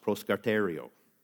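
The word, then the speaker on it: proskartereo (προσκαρτερέω): It's actually pronounced proskartereo, which I couldn't think of a visual for that. So we're just going to go with proskater oreo. It's close enough.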